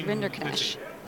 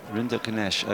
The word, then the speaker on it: Rinderknecht: I've always said it wrong, but everyone kept correcting me, and now it's Rinderknecht. – Rinderknecht, okay.